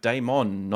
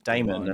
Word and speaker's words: Damon: but like you have to kind of say my name in a French way. It's like Damon, not Damon.